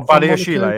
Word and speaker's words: Badiashile: Badiashile? – Oh, Badiashile, yeah.